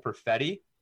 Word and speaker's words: Perfetti: and are allowing Cole Perfetti, did I say that right? – Yeah, you did.